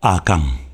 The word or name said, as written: Akang